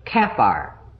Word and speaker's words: Kaphar: K-A-P-H-A-R. K-A-P-H-A-R. Kaphar is the way I would pronounce it. I could be wrong on that.